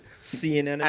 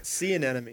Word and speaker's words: sea anemone: Sea anemone. – Animosity? – The freaking thing they lived in. – Sea anemone. – Oh no, the anemone.